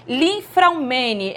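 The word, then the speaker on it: Li-Fraumeni: de câncer e ele descobriu que tem a síndrome Linfraumene. É assim que se pronuncia? – Isso mesmo.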